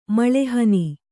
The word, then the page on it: ಮುಳ್ಳುಹಣ್ಣು: muḷḷu haṇṇu